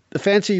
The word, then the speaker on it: Fante: Fante.